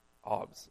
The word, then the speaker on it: alms: Is it alms or alms? Alms. Okay, I'll say alms.